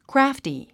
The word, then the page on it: crafty: krǽfti; クラァフティ